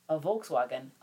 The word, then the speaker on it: Volkswagen: And a Volkswagen, which is actually the German.